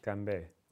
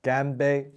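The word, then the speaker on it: Ganbei (干杯): Ganbei. – Ganbei? – Ganbei.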